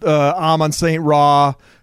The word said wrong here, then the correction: Amon-Ra St. Brown: I don't think I said his name right. Amon-Ra St. Brown. He's got an awesome name that I can never say right.